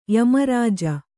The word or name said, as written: ಯಮರಾಜ